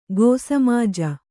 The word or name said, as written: ಕ್ಷ್ಮಾಜ